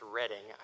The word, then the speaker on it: Reading: It's spelled Reading, but it's pronounced Redding.